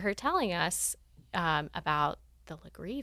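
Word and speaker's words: Legare: it's spelled Laguerre, but I'm told that to pronounce it Legree.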